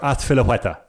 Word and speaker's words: Azpilicueta: No, Cesar Azpilicueta. Yes. You've got 4 from 5.